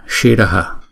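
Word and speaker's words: śiraḥ: Chữ shiraf đứng trong bài kệ này chúng ta đọc là shiraf, đứng một mình thì chúng ta đọc là shiraha là đầu.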